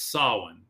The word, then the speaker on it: Samhain: Samhain, I think is actually how you say it. Or Samhain. It's Samhain. Excuse me.